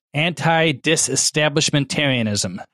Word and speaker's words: anti-disestablishmentarianism: It says anti-disestablishmentarianism.